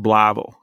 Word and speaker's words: Blytheville: It's pronounced Blavel if you're from there.